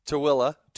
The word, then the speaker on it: Tooele: Tooele.